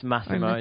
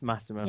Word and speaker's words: Massimo: And most of the time is the first name that also gets butchered as Massimo, but you did it right. – Okay. So it's Massimo. – It's Massimo. Yeah, it's Massimo.